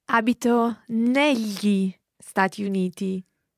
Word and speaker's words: negli: It's spelled N-E-G-L-I. So that G-L is the same GL that you hear in the word tagliatelle. So it's a very common sound in Italian, but in this word, it's pronounced negli. Negli. It's like writing N-E-L-Y-I, if you like.